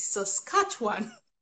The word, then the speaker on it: Saskatchewan: Well, the first thing I'm going to tell everyone who's looking at this province and interested here is that the correct pronunciation is Saskatchewan.